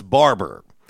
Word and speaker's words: Barbour: Barber is the brand. Like, you know, you get your hair cut. Yeah. Barber. It's spelled B-A-R-B-O-U-R, but it's Barber.